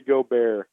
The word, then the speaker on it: Gobert: Rudy Gobert. – Ah, I was going to say Gobert.